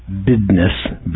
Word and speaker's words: business: Remember, that's pronounced BIDNESS, B-I-D-N-E-S-S, saying Texas is a right-to-work state with low unionization of the manufacturing workforce.